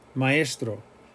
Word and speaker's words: maestro: Example, maestro, maestro. That's not a diphthong, even though it might sound like I, maestro. Maestro would be if you pronounce it like a diphthong, maestro. But we're saying maestro, maestro. We're hitting the A and we're hitting the E.